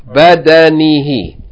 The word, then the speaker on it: بدنه: No, badan, badan. Fatha, fatha. Badanihi. Yufidu al-ma'a ala badanihi.